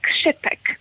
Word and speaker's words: Čipek: Men ska man vara väldigt petig så ska det uttalas Čipek.